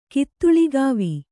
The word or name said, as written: ಕಿತ್ತುಳಿಗಾವಿ